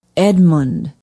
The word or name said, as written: edmund